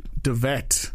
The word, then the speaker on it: De Wet: For the purposes of the Meg, it is spelled W-E-T. And from what I understand, he is a very good special effects artist. – If a man's name is spelled W-E-T and he's working on the Meg, his name is pronounced Wet. – Oh, I know. And that's probably why the PR people specifically said it's De Wet.